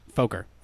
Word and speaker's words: Foker: So I think it's a nice marriage of a lot of technology in the existing medical practice of the Forker technique. – Yeah, it's Foker. we've both been saying it wrong.